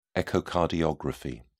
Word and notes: echocardiography: British English

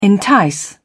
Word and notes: entice: British English

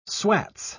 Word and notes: swats: British English

